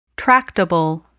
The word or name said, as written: tractable